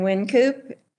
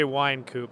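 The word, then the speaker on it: Wynkoop: I'm going to say Winecoop. – You're going with wine, not win. – Yeah, wine. Yeah. – Why? – Because there's a Y, I guess. – W-Y-N-K.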